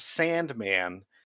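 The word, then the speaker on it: S&Man: This one is pronounced Sandman, but it's actually spelled S-Man.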